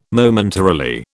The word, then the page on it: Momentarily: British English